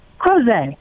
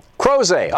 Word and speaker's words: Crozet: Am I saying that right? – Crozet. – Crozet.